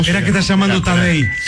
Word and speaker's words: Tadej: Tadej. Como Tadeo, pero Tadej Pogačar. Pogačar se escribe.